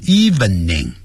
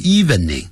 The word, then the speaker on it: evening: 记住不要念成 evening，叫 evening eve，再加一个 ning 的音，好多呢。我们老中念成 evening，不是 evening， eve 加个 ning 的音， evening， evening，特别要记住。